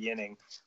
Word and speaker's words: Ginning: Adam Ginning. – Yeah, so and I think it's actually, I think it's a silent G to add confusion to the Swedish guys. I think it's Adam Ginning.